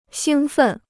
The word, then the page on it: 兴奋: xīng fèn